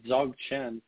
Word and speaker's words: Dzogchen: But there are also methods, you know, ancient methods called Dzogchen. That's actually spelled with a D-Z-O-G-C-H-E-N is how you spell that.